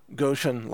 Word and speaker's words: Gotion: Gotion is spelled, it's just like the word motion, but a G instead of an M. Okay, so Gotion like motion.